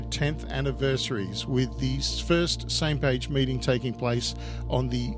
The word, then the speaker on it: SMPAG: It's pronounced Same Page, S-M-P-A-G, Same Page.